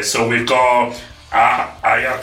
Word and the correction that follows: Aja: Kong. – Aja. – Alright. – You just corrected him.